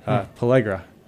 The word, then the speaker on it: pellagra: How do you pronounce it? Pellagra. – Pellagra? – Yeah.